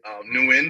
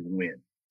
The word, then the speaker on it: Nguyen: But the proper way to say it is actually Win. So the ending is actually, yeah, it's actually silent. – That's right. – So the U-Y-E-N is Win.